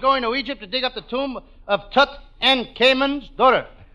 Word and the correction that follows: Tutankhamun: How do you say it? How it? Tutankhamun.